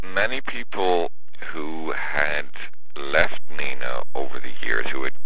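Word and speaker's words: Nenagh: Nina, that's how you pronounce it, correct? – Yes, it's pronounced Nina, like the girl's name.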